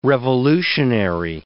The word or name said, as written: revolutionary